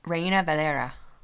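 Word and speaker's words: Reina-Valera: Oh, Reina Valera.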